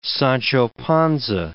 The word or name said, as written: Sancho Panza